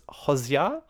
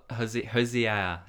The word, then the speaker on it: Hozier: Recently went over to New Zealand to see a little band called, I believe it's pronounced Hozier. – Hozier. Yeah. Hozier. So I just boot scooted over there for the weekend.